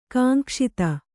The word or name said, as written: ಕಾಂಕ್ಷಿತ